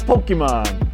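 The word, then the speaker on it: Pokémon: It's Pokemon. Pocket Monster. It's po- Pocket Monster.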